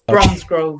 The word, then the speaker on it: Bromsgrove: Did I pronounce the name correct of the place that you live? – Yes, Bromsgrove.